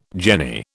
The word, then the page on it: jenny: British English